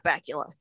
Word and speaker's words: Bakula: And I love Scott Bakula. His name rhymes with Butt Crackula.